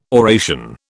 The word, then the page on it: oration: British English